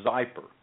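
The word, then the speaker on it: Xypper: You just need to download the Zyper app to your smartphone. And let me spell that. It's X-Y-P-P-E-R, Zyper.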